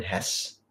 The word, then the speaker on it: Hesse: So today we're going to talk about Hermann Hesse, and it is pronounced Hess.